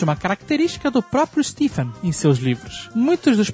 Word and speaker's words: Stephen: Stephen King é meu autor favorito e gostei muito de ouvir vocês falarem dele, exceto quando falavam o nome. A pronúncia é Stephen que eu tô falando aqui.